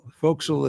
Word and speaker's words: forecastle: Forecastle is F-O-R-E-C-A-S-T-L-E. It's pronounced forecastle.